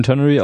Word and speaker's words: Ternary: Genau, was ich gut finde, Ternary, wie spricht man das aus, sag mal? – Ternary. – Den Ternary Operator, den hat's ja schon eine Weile.